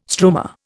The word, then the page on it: stroma: Indian